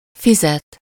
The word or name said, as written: betalen